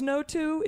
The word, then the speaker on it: Noto: And that might be pronounced Noto. It's N-O-T-O.